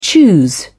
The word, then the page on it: choose: British English